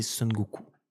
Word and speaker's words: Son Goku: Ouais tu vois, j'ai pas l'accent chinois, donc je vais pas le prononcer à la française, Sun Wukong, le caractère chinois de, cette, de ce nom-là, les Japonais, quand ils le lisent, c'est Son Goku.